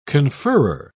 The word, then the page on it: conferrer: British English